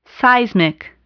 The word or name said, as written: seismic